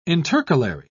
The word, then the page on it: intercalary: British English